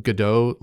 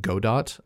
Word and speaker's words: Godot: Spelled out, Godot looks like Godot, but it's pronounced Godot as far as I understand.